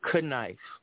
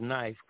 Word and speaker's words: knife: Why do we have silent K's in front of certain words? Knife instead of knife, knife or knife, silent K.